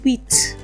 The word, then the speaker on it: huit: 8 is pronounced huit. Huit. As usual, like the 6, the T is not pronounced when it's before another word.